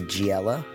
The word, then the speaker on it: Geexella: She spells her name G E E X E L L A. It's pronounced Giella, I hope.